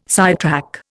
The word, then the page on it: sidetrack: Indian